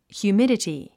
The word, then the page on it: humidity: hjuːmídəti; ヒューミデティ